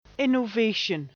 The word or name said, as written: innovation